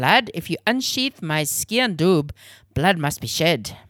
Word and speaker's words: sgian dubh: It's spelt skean dub. – S-G-I-A-N-D-U-B-H. Grandad repeated the Gaelic word slowly.